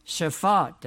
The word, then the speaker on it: Shaphat: Shaphat, that's good, okay.